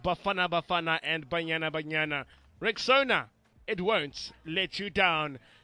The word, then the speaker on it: Rexona: A friend of mine called it Rexona, but guys, it's Rexona, like Rexona, Rexona.